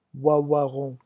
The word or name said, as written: ouaouaron